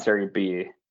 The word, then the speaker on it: Serie B: How do they pronounce it in Italian? It's not Serie B. – Well, it's Serie B, but the official name is Serie BKT, and that's sponsor reason.